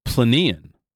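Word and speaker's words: Plinian: And then there's an even more volcanic, more violent eruption called Planean, I believe is how it's pronounced. – I think so.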